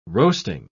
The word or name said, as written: roasting